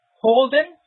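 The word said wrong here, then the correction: Auden: am I saying it correctly? Orden, I think it is.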